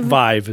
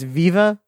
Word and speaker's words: Vive: Vive is the pronunciation. – I didn't read any of the reviews, but... – You know, I've been reading it as Viva the whole time.